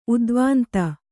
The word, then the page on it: ಉದ್ವಾಂತ: udvānta